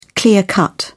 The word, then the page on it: clear cut: British English